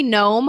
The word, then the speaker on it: Gnome: I am Noey Gnome on almost everything. It surprises me people misspell gnome all the time. So there is that silent G, Noeygnome.